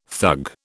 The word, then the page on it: thug: British English